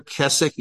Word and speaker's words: Keswick: In each of these perspectives, by the way, this name here, Keswick, is not a person. It's a place in England, the Keswick movement. And you know it's English because they don't pronounce their W.